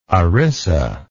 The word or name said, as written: ERISA